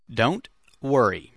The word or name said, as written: don't worry